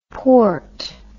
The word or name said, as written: poort